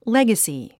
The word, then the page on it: legacy: léɡəsi; レガスィ